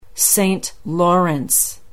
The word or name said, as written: Saint Lawrence